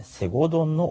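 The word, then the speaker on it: 西郷どん: このキャンペーンでは新幹線くじの他にも大河ドラマ読み方が NHK の大河ドラマですね、セゴドンの放送開始に合わせ西郷隆盛ゆかりの地に関する企画などが展開されますということですんで、まあ新幹線に乗る予定がある方は 3 月いっぱいまでのところでね、くじを引いてみてはいかがでしょうか。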